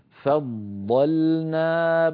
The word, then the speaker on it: فضلنا: مر معنا في الآية الأولى التي قرأناها وهي الآية 253، حرف الضاد في كلمة فضلنا بعضهم على بعض، ثلاث ضادات، الضاد الأولى مشددة فض فض، فننطقها بهذا الشكل فضلنا، فض فاء مرققة، ضاد مفخمة بعدها لام مرققة،